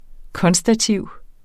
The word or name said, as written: konstativ